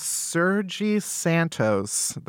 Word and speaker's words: Sergi Santos: Next up in love troubles, a Spanish man named Sergi Santos, that's the Spanish pronunciation, created a sex doll equipped with the latest advancements in artificial intelligence.